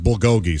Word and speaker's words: bulgogi: Surely after his time in Korea, he's familiar with butgogi, B-U-T-G-O-G-I, a couple of steaks marinated teriyaki sauce overnight and then grilled. – It's actually pronounced bulgogi, so I don't even know if I want to listen to this guy.